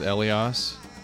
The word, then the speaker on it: Elias: I read that it was written about a gardener named, and you're going to have to help me out, it's Elias. Am I saying it correctly? – I've always pronounced it Elias.